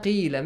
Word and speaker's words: قِيلَ: So, وَإِذَا قِيلَ, make sure the pronunciation of the letter قَاف. And after قَاف, we have like that long vowel sound, the يَامَدِي. وَإِذَا قِيلَ قَالَ قِيلَ, the same sound, okay? The same long sound.